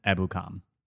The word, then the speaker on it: Ebukam: We knew they were going to address this position at some point, and they did with former Los Angeles Ram Samson Ebukam. I've been thinking about pronouncing this name for so long. – Killing it.